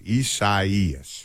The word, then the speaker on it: Isaias: That's how you pronounce it, Isaias.